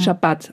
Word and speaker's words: Schabbat: Schabbat, auf der zweiten Silbe betont.